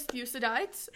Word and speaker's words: Thucydides: Thucydides. – What? – Thucydides. – Thucydides. – No one calls him that. My professor did not call him Thucydides. – So, according to Google, his name is Thucydides.